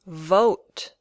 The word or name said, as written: vote